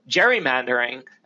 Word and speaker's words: gerrymandering: or gerrymandering, as I maintain it should be pronounced, the G being soft.